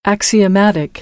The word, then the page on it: axiomatic: American English; ɾ